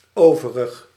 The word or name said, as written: autre